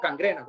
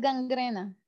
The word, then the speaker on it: gangrena: ¿Gangrena o cangrena? ¿Cómo se dice eso? Bueno, que se, se, pudre o la infección, ¿verdad? – Creo que es eh, gangrena. ¿Ah? – Creo que es gangrena. – Gangrena, sí, gangrena, A mí dice mi esposa también.